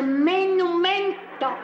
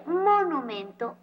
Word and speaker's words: monumento: No se dice estoata, se dice menumento. – Pero qué mal están los dos. Ni se dice estuata ni se dice menumento. Se dice monumento o estatua.